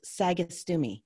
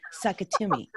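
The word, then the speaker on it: Sagastume: My last name is pronounced Sagastumi. It's Spain from Spanish. It's Spain. It's Spanish from Spain. Yeah. But they somehow started saying Sakatumi. Sakatumi.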